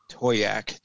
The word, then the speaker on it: Toyak: Toyak, I actually pinged Toyak to ask him how he pronounced his name, and he said it's Toyak. – The most asked question of his gaming career, but it's the word toy and ack, so Toyak.